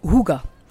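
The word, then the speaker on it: hygge: Initialement, le Hig, c'est la philosophie de vie en Scandinavie, qui est de créer une atmosphère cocooning et chaleureuse. Et ça se prononce Huga mais Berber Hig,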